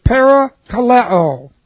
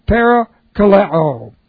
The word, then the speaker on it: parakaleo: And it is parakaleo. And it looks like this. Parakaleo. P-A-R-A, that's a preposition. K-A-L-E, long O. Parakaleo.